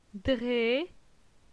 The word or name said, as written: Dre